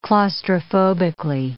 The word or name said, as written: claustrophobically